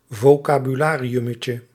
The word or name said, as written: vocabulariummetje